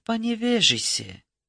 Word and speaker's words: Паневежисе: Была ли в Паневежисе? Ударение она делает на «и».